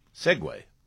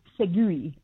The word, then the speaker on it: segue: This is one that I hear all the time mispronounced, and it stuns me, and that is S-E-G-U-E. – Segway. – That's right, but you'd be astonished how many people say either seg, just seg, I suppose because they think it's a word like league, if you know what I mean, that the U-E is silent.